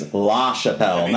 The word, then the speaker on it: LaChapelle: I'm like, well, first off, I said my last name is LaChapelle, not Chappelle.